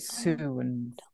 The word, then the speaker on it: sowans: You spell it S-O-W-A-N-S, but you pronounce it soon.